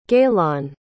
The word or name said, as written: gaylonn